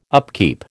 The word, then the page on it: upkeep: American English